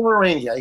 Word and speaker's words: Arania: And at this time, would you like to talk about the Manual of Arania and your, am I saying that right? – Yes, you are saying it right. – Awesome. – You pronounced it correctly. The last guy didn't pronounce it correctly. Manual of Arania, yes.